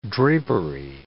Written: drapery

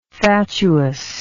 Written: fatuousness

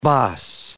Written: baas

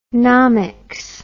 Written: nomics

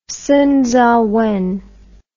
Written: Song Ziwen

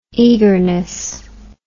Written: eagerness

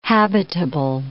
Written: habitable

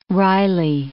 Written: Riley

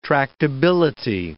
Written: tractability

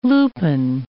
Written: lupine

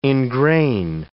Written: engrain

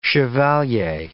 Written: chevalier

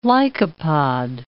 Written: lycopod

